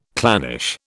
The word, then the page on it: clannish: British English